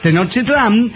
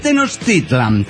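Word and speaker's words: Tenochtitlan: Lo que nunca falla, si tiene alguna duda en la pronunciación, recuerde que el acento va siempre en la penúltima sílaba. ¿Me entiende? Póngale, muchos dicen Tenochtitlán y no es Tenochtitlán. Ah, bueno, gracias. Claro, o Popocatépetl, no es Popocatépetl, y así.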